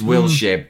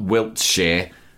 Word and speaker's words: Wilshere: Although it's spelled Wilshere, isn't it? – It's Wilshere, not Wiltshire.